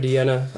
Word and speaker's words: Deanna: Songs for Deanna, available on all streaming platforms. – That's how you pronounce it? – Yeah. Like Dirty Diana.